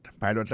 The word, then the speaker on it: pilot: Pilot là, c'est même mot pilote là, il est tiré E-A-D-L-I. Il écrit P-I-L-O-T. Alors, il s'est prononcé I dans le cas. Alors, et puis, dans le mot pilot là, tout, après accent tombé sur le premier syllabe là.